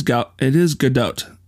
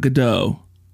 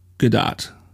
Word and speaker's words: Gadot: It is Godot. Godot. It's not Godot. It's not French. She's Israeli. And it is pronounced Godot.